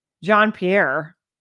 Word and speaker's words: Jean-Pierre: Back up here with Jean-Pierre. That's how you pronounce her name.